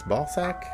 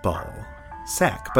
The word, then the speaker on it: Balzac: All right, so whether you say Balzac with a Z or an S sound, just like Liza, you're still welcome here on Primal Ice Cream.